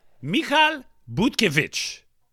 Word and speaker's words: Butkiewicz: Michal Butkiewicz. Wisz. W-I-C-Z. How would you pronounce that? – The way you're pronouncing it.